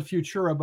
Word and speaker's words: FVTURA: So it's, you call it fyoo-chura, but it's like spelled a little funky.